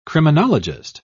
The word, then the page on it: criminologist: British English